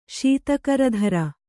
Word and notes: ಶೀತಕರಧರ: kannada

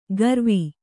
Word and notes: ಕಾರವಿ: kannada

